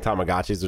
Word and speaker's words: Tamagotchi: By the way, I know he's saying Tamagotchis, which is like a funny thing to say, but they're Tamagamis, I believe is how you say it, right? – Yeah, Tamagotchi is correct.